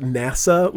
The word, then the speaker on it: NASA: Um, she was from Maine and pronounced NASA like the space ship Nasser. Yeah, Nasser. – I'm like, it's an acronym. There's no R. you can't just add an R to.